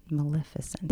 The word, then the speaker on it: Maleficent: Maleficent. – Maleficent.